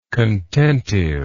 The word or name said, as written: contentive